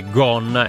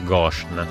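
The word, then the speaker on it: Ghosn: Vabbè, posso allora solo ringraziare un altro ascoltatore che ci dice che il nome dell'amministratore, l'ex amministratore delegato di Renault Nissan, è Gon e non Goshen. Si pronuncia così.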